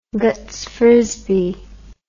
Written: Guts Frisbee

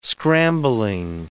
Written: scrambling

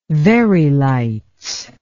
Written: very lights

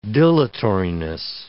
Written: dilatoriness

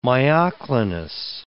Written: myoclonus

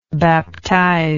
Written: baptizer

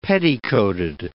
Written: petticoated

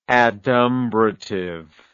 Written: adumbratively

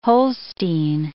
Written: Holstein

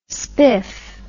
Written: spif

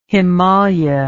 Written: himalia

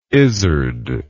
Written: izard

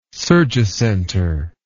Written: surgicenter

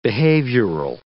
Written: behavioral

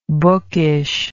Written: bookishness

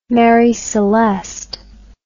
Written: Mary Celeste

